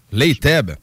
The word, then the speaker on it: Layteb: Layteb. Je pense que je le prononce comme faux. – Il est correct, mais.